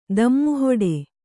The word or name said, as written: ದಿಮ್ಮುಹಿಡಿ